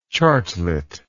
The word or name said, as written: chartlet